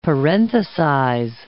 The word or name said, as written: parenthesize